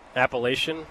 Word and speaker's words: Appalachian: Appalachian? – It is Appalachian State.